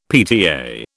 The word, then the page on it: PTA: British English